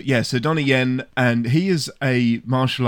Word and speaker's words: Donnie Yen: But this has got Donnie Yen, who is of Rogue One fame.